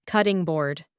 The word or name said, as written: cutting board